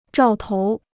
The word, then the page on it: 兆头: zhào tou